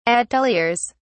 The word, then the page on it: ateliers: British English